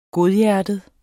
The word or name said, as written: godhjertet